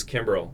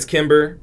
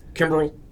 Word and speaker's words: Kimbrell: My name is Kimbrell. – Yeah. – But Kimber? – It's Kimber or it's Kimbrell.